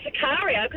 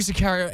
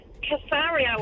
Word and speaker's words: Sicario: Also you need to learn to say it properly because you were calling it Casario or something weird.